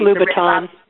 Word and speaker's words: Louboutin: I don't even know how to say his name correctly. It's Louboutin.